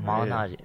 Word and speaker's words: Malignaggi: But, yeah, no, not Malignaggi. Took me a while to pronounce his name. – Malignaggi.